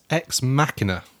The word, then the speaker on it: Ex Machina: After his much-acclaimed Ex Machina, or Ex Machina? – It's Ex Machina, cool. – I believe. – So I pronounced it right the first time.